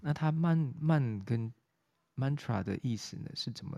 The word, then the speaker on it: mantra: 好，慢其实是心智，那 tra 它是一个震动，它念起来呢，因为呃我们一般 mantra 的这个发音，它比较。呃，比较像是这个呃西方在读音的音节，那其实它原本的发音应该是 mantra，mantra，它的后面是一个呃震动音 mantra，好 t r a，那这个这个后面这个是一个震动跟一个方向，就是 t r a 的意思，嗯，所以这个 mantra mantra 的科学基础呢，是建立在声音是一种能量形式的认知。